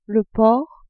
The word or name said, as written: porc